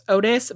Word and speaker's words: Ottis: So, after some time, the investigation, the police eventually conclude that Adam was abducted by a drifter by the name of, and I've heard it pronounced Otis, but it's spelled O-T-T-I-S, so I think it's Otis Tool.